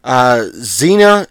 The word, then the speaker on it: Xenia: Xena, it's pronounced, that's probably how it's pronounced, but it's spelled X-E-N-I-A.